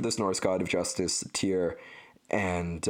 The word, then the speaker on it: Tyr: T-Y-R. I've heard them pronounce it more like Tuir, or like Tyr, or, you know, Tyre, or I don't know. I think it's Tyr. I'm just going to pronounce it Tyr.